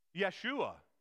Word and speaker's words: Yeshua: They're the same. Yeshua. Joshua is how we pronounce essentially Yeshua in English. It's how we look at the Hebrew word and we bring it into English as Joshua.